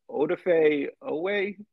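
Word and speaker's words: Odafe Oweh: And Brett got Damian Harrison, Odafe Owe, the Ravens defensive line.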